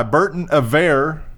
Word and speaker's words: Berton Averre: It was written by Berton Averre. – Is that the pronunciation? Yeah.